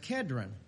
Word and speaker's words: Kedron: He said, and I'll probably get it wrong exactly, but he said in the New Testament, the pronunciation is Cedron. This doesn't matter, but it's Cedron, same brook in the Old Testament, or back in those days. It's not even Hebrew, it's the same word, but it's pronounced Kedron.